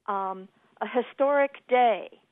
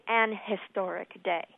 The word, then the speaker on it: historic: So we say a hotel, a historian, a house, a hot dog, a historic day. We don't say an historic day. That's a mispronunciation.